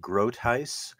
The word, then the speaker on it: Groothuis: yes, it's a Dutch name, so we pronounce it Groothuis, which is kind of an amalgamation of English and Dutch.